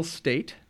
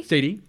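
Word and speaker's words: Staite: Played by Jewel State. – Stady.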